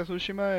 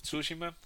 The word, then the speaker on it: Tsushima: sinceramente he escuchado que lo pronuncian de las dos maneras. En, eh, inglés en inglés se pronuncia Tsushima, en español Tsushima.